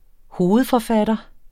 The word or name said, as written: hovedforfatter